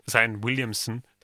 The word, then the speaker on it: Zion Williamson: Aber die Kombination mit Ingram, mit Zion Williamson und dem CJ McCollum, die funktioniert super, harmonieren richtig mhm. gut. – Das einzige Schade ist, letzte Nacht hat äh, Zion Williamson bei einem Dank, Zion Williamson,